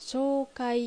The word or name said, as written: Shoukai